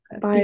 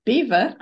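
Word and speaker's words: Biewer: Oh, pronounced Beaver. Oh, Beaver Terrier. – Yeah, Interesting. – that's yeah. – That is really, really fascinating, actually. So yeah, sorry, the Beaver Terrier, I did pronounce it wrong before, is a calm dog breed with an irresistibly cute, angelic face and a charming little smile.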